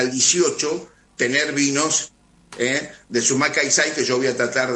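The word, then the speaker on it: Sumak Kawsai: Te lo deletreo, S-U-M-A-K, A-K. – Sí, Sumac. – Sumac, K de kilo, A-W-S-A-I, Caixay. Así se pronuncia. Sumac Caixay, en lengua cacán, quiere decir buen vivir.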